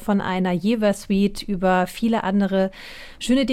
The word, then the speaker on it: Jever: Also man spricht Jever eigentlich als Jever aus.